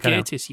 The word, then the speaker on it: Gatiss: Gatiss adaptation of Good Companions is now arriving on the BBC. – I think it's pronounced Gatiss, you know, but...